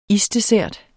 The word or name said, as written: isdessert